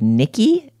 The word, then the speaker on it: Knickey: Until now, when I have discovered a brand called Nikki. It's spelled like Knickers, but E-Y, K-N-I-C-K-E-Y.